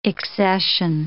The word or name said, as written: accession